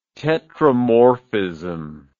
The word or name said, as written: tetramorphism